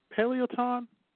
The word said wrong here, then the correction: Peloton: It's Peloton.